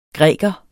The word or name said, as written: græker